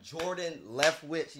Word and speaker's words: Jordan Leftwich: Well, everybody, today we have Jordan Leftwhich.